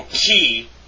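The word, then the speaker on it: chi (χ): Number three, in Koine Greek, the letter chi was probably pronounced like ch in Scottish, lock, or the German bach. Since this sound does not occur in the English, the ch sound in chemist may be used instead. So chi is like the ch sound of chemist.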